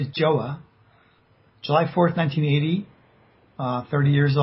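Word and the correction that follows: João: It's Joao. It's like wow, but with a J. – Oh, Joao.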